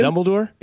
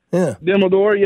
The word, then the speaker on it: Dumbledore: So people asked me to be, Dimmeldorf? How do you say his name again? – Dumbledore? – Yeah. Dumbledore, yeah.